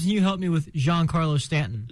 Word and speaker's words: Giancarlo Stanton: They have 2 of the best hitters in baseball, and Giancarlo Stanton,